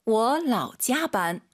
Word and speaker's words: 我老加班: 我老加班. – 라오는 우리 왕 선생님처럼 올라가면 안 되죠. 워라오 해도 알아듣지만 틀린 발음이에요. 뒤에가 일성이니까 반삼성 워라오.